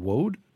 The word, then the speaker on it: woad: It was, I guess, something in the British Isles called woad. Am I saying that right? – You are. Yes, woad is a plant from the mustard family.